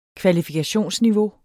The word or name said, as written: kvalifikationsniveau